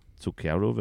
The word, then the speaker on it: Zucchero: der liebe Künstler, nämlich Zucchero. Zucchero wird dieser äh, Künstler ausgesprochen.